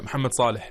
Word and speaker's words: Mohamed Salah: Hey, see, you pronounced it right, Mohamed Salah.